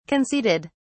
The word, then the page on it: conceded: British English